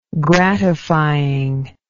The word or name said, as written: gratifying